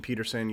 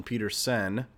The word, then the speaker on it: Petersen: It's not, you know, if you search The Libertarian Republic or Austin Peterson, you may not find it. Of course, my last name is spelled P-E-T-E-R-S-E-N, Austin Peterson.